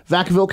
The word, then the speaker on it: Vacaville: We were doing a bunch of shows going down the West Coast, and we stopped in, I think, Vacaville, if I'm saying that right. – Excuse me? – Vacaville, California, yeah. Which I think literally means cowtown in Spanish, Vacaville.